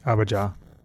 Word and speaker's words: Abijah: And we know Zechariah was of the house, of Abijah. I don't know how to say it. – Abijah.